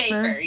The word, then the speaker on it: Schaefer: Schaefer, yeah. Right? Like wafer? – Schaefer, yeah.